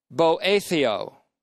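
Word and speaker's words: boetheo (βοηθέω): This time the word is boetheo, B-O-E, long E that is, T-H-E-O, long O.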